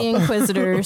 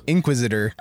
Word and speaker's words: Inquisitor: Inquisitor. – I'm scaring myself. – Inquisitor. – You're putting the wrong emphasis on the wrong syllable.